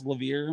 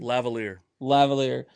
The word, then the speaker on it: lavalier: I bought, is it pronounced Lavier microphone? – Lavalier.